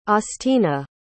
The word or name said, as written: austina